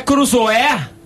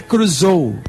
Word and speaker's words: Crusoe: Não é Crusoé? – Não, a pronúncia certa é Crusoe.